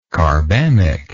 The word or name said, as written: carbamic